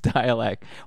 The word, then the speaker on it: Dälek: But yeah, this is how I knew they were pronounced dialect.